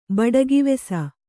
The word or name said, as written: ಬಡಗಿವೆಸ